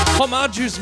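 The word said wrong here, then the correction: Hommarju: Do you mean homage? Yes, I've been pronouncing his name wrong this entire time, so I do apologize, homage.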